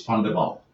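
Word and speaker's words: van der Wall: So yes, my surname is, and I always spell it because if I say it then people get it wrong, so I'm going to spell it and then I'll say it. It's V-A-N space D-E no R space W-A-L-L. So it's Van der Val.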